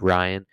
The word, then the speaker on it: Ryan: I'm your host, your online health and wellness coach, Ryan Kassam. I shouldn't do an accent when I'm saying my name, because a lot of people call me Brian on the internet, even though it's Ryan.